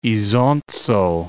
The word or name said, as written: Isonzo